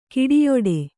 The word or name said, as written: ಗೆಡೆಯಾಟ